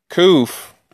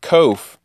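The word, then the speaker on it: Kuf (קוף): Kuf, sometimes pronounced Kof, is the voice, which is Kol, of a person.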